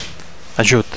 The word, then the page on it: ajut: Catalan